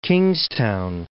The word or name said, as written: Kingstown